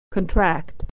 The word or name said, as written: to contract